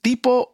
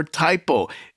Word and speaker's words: Typow: You could pronounce it Typow or Typow. It's spelled T-Y-P-O-W, and the song is called Pose.